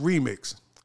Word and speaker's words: RYMKS: So it's pronounced Remix, but it's spelled R-Y-M-K-S, which is in Arabic, which is the third most spoken language in Africa.